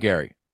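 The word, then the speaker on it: Gerry: In defense of Gary, right? Gary, not Jerry. It's spelled Jerry, but pronounced Gary.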